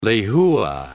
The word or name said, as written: lehua